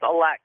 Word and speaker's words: Aehlex: It's Alex. And the way I get people to remember it these days is that aliens eat humans, Lex. it's spelled A-E-H-L-E-X, and no one's ever...